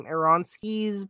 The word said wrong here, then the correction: Aronofsky: protozoa. – Aronofsky. Aronofsky.